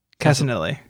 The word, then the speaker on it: Cassinelli: Uh, Matthew Casanellini. How would you say that? I would say Cassinelli.